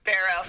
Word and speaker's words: Barrow: Did I say that right, or is it Barrow? I literally just asked you 1 second ago. – It's Barrow.